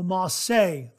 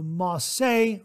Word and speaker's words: Marseille: Um, now I wasn't pronouncing it correctly. he corrected me on this uh, this was the um, uh, Ma, the, the Marseille, the Marseille razor.